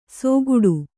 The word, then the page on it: ಸೋಗುಡು: Kannada